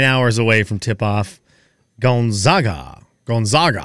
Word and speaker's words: Gonzaga: Like a gun. Gonzaga.